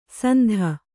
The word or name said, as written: ಸಂಧ